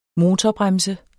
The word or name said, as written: motorbremse